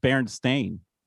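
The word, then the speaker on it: Berenstain: You said it right. It's pronounced Berenstein.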